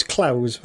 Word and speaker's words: Clowes: I always pronounce that as Clowes. I might be wrong. – You probably are right.